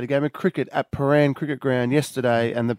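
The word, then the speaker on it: Prahran: Paran.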